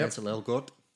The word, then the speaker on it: Ansel Elgort: Ansel Elgort. Am I saying that correctly? – Yeah, Ansel Elgort.